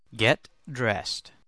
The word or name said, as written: get dressed